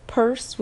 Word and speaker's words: Pers: Purse is not the name that my mother calls me, but it is the name that I use for branding purposes, and it's just Purse, which is the beginning part of personally, and it's spelled P-E-R-S, since you were wondering.